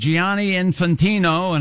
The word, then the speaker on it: Gianni Infantino: FIFA president Gianni Infantino, and I'm just doing this story because I'm proud of the way I'm pronouncing Gianni Infantino.